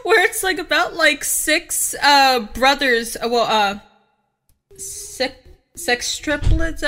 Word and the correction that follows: sextuplets: Sextuplets? – Yeah.